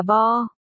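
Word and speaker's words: bo (白): Trong kinh kịch, mọi người không phát âm chữ này là bay mà là bo.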